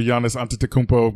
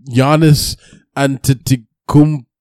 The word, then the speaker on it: Giannis Antetokounmpo: Yeah, but obviously, shout out to Giannis Antetokounmpo because he had 24 points, 9 rebounds, 4 assists.